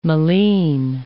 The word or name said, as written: malines